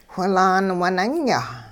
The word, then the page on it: whilaan winunya: hupa